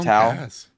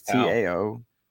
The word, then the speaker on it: Tao: Um, Just but Tao, Tao, it's T A O Tao.